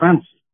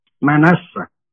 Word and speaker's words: منسى: اسم منسى بينطقوه غلط احيانا، يقول لك منسي، لا هو مش منسي، منسى من ينسى،